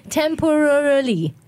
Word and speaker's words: temporarily: Temporarily， you have five syllables in this word.